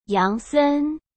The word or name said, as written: Yang Sen